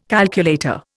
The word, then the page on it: calculator: Indian